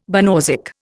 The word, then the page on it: banausic: Indian